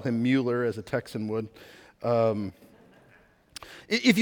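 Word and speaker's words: Müller: And so his actual pronunciation, this is what he looked like later in life, would be Mueller or Meller, really depending on what person you read.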